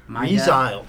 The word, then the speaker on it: resile: Resili. Is it Resili? – Let me see. – I don't know. – Resile. – Is it leprosy? – Resile. – Okay, it's resile.